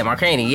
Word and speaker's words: Markkanen: The Bulls acquired Zach LaVine, Chris Dunn, and also Arizona forward Lauri Markkanen. Am I saying his name right? Markkanen? – I think so. – Yeah, Markkanen, yeah.